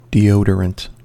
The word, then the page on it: deodorant: American English